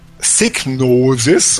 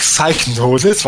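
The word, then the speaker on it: Psygnosis: Äh, Mike Clark dürften den, den meisten bekannt sein durch seine Arbeit beim englischen Publisher Signosis und nicht Psychnosis,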